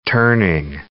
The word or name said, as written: turning